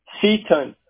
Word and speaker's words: Seaton: Seaton. S as in Sam, E-A-T-O-N.